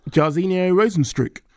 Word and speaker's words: Jairzinho Rozenstruik: Jairzinho Rozenstruik.